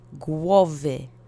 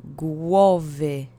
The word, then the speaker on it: głowy: So, accent is on the first syllable. Let's say it together three times. Głowy. Głowy.